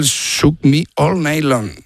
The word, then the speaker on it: Shook Me All Night Long: Lo has dicho en francés. – O sea, has cambiado cuatro palabras de sitio. – Has engolado tanto que se te ha pasado de idioma. – Qué grande, tío. Qué me cuesta el Shook Me All Night Long. – Ahí mucho mejor.